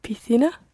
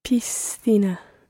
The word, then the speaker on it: piscina: You pronounce the S as part of the syllable pis, and then the next C is pronounced as a TH, so thina, piscina. – Piscina. – And in Latin America you would hear piscina.